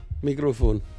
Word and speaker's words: Mikrofon: Mikrofon. – Oh, it's kind of really. – Similar. – It's very similar. – Yeah. – So not microphone, microphone.